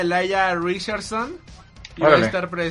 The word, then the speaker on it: Elijah: se escribe Elijah. – Sí, pero se pronuncia Elijah, ¿no? – Elijah, ah, ok.